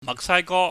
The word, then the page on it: mahksaigo: cantonese